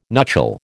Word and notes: nuchal: American English

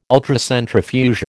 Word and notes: Ultracentrifuge: American English

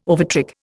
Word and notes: overtrick: Indian

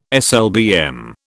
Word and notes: SLBM: British English

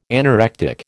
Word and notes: anorectic: American English

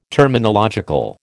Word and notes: terminological: American English

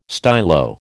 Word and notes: stylo: American English

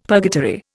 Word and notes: purgatory: Indian

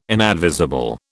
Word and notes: inadvisable: British English

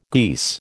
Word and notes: geese: American English

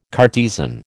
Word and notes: Cartesian: American English

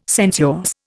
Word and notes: sensuous: Indian

